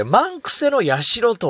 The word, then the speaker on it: 万九千社: 漢字で万、そして 9、 0 0 0 と書いて、これ、万癖、万癖とかという読み方をするらしい。それで、えー、神社の社とつけて、万癖の社と読むんですね。